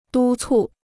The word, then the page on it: 督促: dū cù